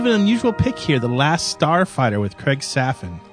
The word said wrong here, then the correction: Safan: I believe that's pronounced Safan.